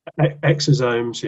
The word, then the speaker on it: exosomes: You mentioned, I'm not going to be able to pronounce them, exosymes? – Exosomes, yeah.